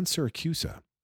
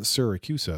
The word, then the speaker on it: Siracusa: And John Siracusa, uh, whose last name I don't pronounce right, you're supposed to pronounce the first syllable uh, like it rhymes with orange or something. But John Siracusa, Siracusa, John Siracusa, John Syriacha was like, no.